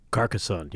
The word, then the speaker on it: Carcassonne: Carcassonne. Is that right? – Carcassonne, yeah. – Yep.